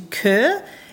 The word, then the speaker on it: Chur: Some of the highlights from where we went, I must say we went to Kerr. It's spelled C-H-U-R, and I always used to call it Chur, which was pretty silly because that's not what it's called.